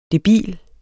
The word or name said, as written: debil